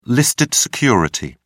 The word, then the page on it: listed security: British English